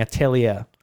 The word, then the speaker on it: Atelier: I've got to get out of the habit of saying Atelier. Apparently Atelier is a French word. The ER is pronounced A.